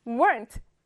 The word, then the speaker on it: weren't: weren't. 기억나지요? 가운데 있는 o를 삭제하고 unt라고 발음합니다. weren't.